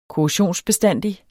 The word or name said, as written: korrosionsbestandig